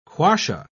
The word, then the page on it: quassia: British English